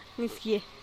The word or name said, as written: nisgie